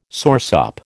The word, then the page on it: soursop: American English